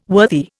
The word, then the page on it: worthy: Indian